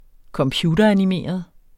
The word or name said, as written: computeranimeret